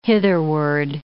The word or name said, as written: hitherward